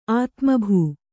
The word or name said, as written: ಆತ್ಮಭೂ